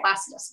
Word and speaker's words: Placidus: The Placidus system. – Yeah, of course. Yes, an awesome pronunciation.